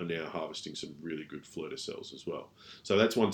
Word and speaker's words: fleur de sel: F-L-E-U-R. This is three words. – Okay. – D-E-S-E-L. Fleur de sel.